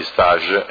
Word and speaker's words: stage: Allora, questa mozione è oggetto stage formativo, si pronuncia in francese questa parola, quindi stage formativo,